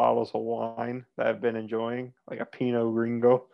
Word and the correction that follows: Grigio: I don't know how to say it the right way. Grigio. So say Grigio.